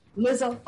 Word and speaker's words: Lizzo: They talk about, I think it's Lizzo. Am I saying her name right? – Lizzo, okay. Lizzo.